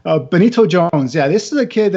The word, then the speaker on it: Benito Jones: Number 9 is Benito Jones from Ole Miss. I said his name right, right? Yeah. – Yes.